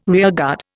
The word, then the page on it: rearguard: Indian